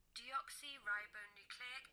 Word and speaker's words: deoxyribonucleic: Deoxyribonucleic acid. – Oh, my God. Oh, God. You do it in the U.S. – Deoxyribonucleic acid.